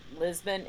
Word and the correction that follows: Libsyn: is it Libsyn? – Yeah. – I don't know. Yes, Libsyn. I can't ever, I always get the B.